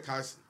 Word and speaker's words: Kai: Am I saying his name right? – Yeah, Kai. Yeah.